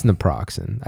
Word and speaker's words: naproxen: Oh, it's naproxen. – It sounded right. – It's naproxen. I just add a couple extra syllables.